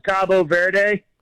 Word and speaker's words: Cabo Verde: In Spanish, it's Cabo Verde. – Cabo Verde. – Cabo San Lucas or whatever in Mexico. Cabo Verde. But okay. The English pronunciation is Cape Verde.